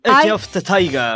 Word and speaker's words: Eye of the Tiger: Eye of the Tiger.